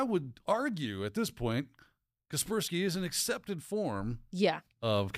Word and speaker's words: Kaspersky: We saw what happened with Kaspersky, which apparently that's pronounced Kaspersky, and I've been pronouncing it wrong.